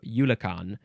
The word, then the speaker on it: Eulachon: Eulachon. Probably from the lower Chinook word, which I cannot pronounce because it's got characters that I am not familiar with. But it's probably also pronounced similar to Yulakan.